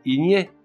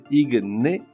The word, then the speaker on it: igné: L'adjectif igné, que l'on peut également prononcer igné, signifie qui se fait sous l'action de la chaleur ou qui est en feu.